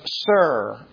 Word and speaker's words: Sur: It is now the town of Sur, pronounced S-U-R, which is Tyre in Arabic.